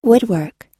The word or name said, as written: woodwork